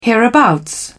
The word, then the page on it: hereabouts: British English